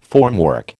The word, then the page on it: formwork: American English